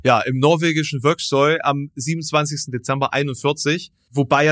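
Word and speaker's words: Vågsøy: Etwa in Operation Archery, äh, was ein Angriff auf eine deutsche Garnison im äh, norwegischen, ich, ich weiß jetzt nicht, wie man es ausspricht, Vöxøy? Wie ja, äh, schreibt man's? Mit einem mit einem Kringel A oben. V Kringel A G S O Y. – Wokschö. – Wokschö. Wokschö.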